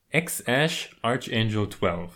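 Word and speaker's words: X Æ A-Xii: Also der Name wird wohl ausgesprochen X-Ash Arch. Nee, warte mal. X-Ash Archangel 12.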